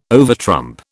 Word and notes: overtrump: British English

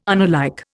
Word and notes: unalike: Indian

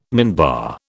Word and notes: minbar: British English

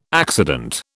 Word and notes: accident: British English

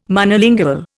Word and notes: Monolingual: Indian

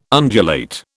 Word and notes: undulate: British English

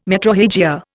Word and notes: metrorrhagia: Indian

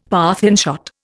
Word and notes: Parthian shot: Indian

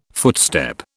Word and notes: footstep: British English

